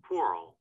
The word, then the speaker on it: whorls: It's whorls. That's the way that's spelled. The way that you pronounce, I'm gonna make sure I'm pronouncing that right. Anyway, now you're paranoid. – Yeah. – Whirl. – It's like whirl. Okay, cool. I'm doing it right.